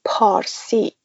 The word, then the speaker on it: Parsi: We call it Parsi. But because Arab people that can't pronounce P, they call it Farsi.